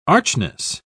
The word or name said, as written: archness